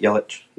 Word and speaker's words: Yelich: Is that how you pronounce it, Yelich? – Yeah.